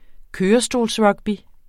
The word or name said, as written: kørestolsrugby